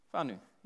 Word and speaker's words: whānau: Whānau, yeah, I knew that.